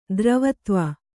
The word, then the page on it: ಧ್ರುವತ್ವ: dhruvatva